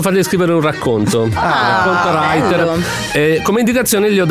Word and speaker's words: Rytr: Come writer, scrittore, ma si scrive R-Y-T-R.